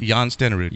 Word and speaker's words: Jan Stenerud: Is that how do you pronounce his name? – Jan Stenerud. – Yes. Jan Stenerud? – Number 3 for Kansas City Chiefs.